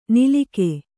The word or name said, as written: ನೀಲಿಕೆ